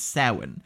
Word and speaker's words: Samhain: By the way, in case anybody who listened to our Halloween 2 episode, that is how you pronounce that word. It is Samhain.